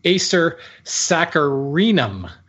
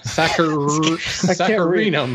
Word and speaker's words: Acer saccharinum: Silver maple is Acer Saccharinum. There's an extra syllable in there.